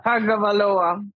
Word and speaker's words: Tagovailoa: Tagovailoa.